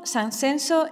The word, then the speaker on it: Sanxenxo: Así, en muchos casos encontrarás una X que se pronuncia como la SH inglesa. Sanjenjo, Sanxenxo en Galicia, Gijón, Sison en Asturias, Játiva, Sátiva en Valencia, etc.